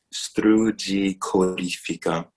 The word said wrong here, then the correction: strugicorifera: Corifera. – Oh, corifera. – Esatto, perfetto.